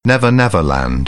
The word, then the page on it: never never land: British English; əɹ